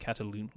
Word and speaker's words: Catalunya: It's a uh, Circuit de Barcelona, and it's C A T A L U N Y A. How do you pronounce? Is it Catalunya? Luna? – Catalunya. – Catalunya. Catalunya.